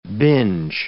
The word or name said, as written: binge